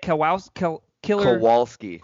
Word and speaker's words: Kowalski: Kowalski.